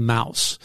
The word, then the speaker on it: Maus: Well, last February, George, the school board in McMinn County, eastern Tennessee, made national headlines after its decision to remove a book called Mouse. Actually, it's spelled M-A-U-S.